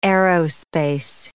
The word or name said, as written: aerospace